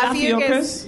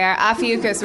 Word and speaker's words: Ophiuchus: Am I pronouncing it wrong? What is it? Ophiuchus? – I know, right? – Adam Goldberg to the rescue. – Is it Ophiuchus? – Yep. – Okay, Ophiuchus, which is November 29th to December 17th, and I was born on December 5th, so.